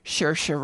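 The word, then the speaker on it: Saoirse: Swarcy Ronan. Shersha. How'd she say her name? Shersha Ronan.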